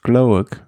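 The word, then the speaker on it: Gloag: Es war entweder Cohen Baufman oder Thomas Glowick. Spricht man diese aus, Glowick? – Ja.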